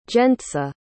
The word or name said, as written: gentza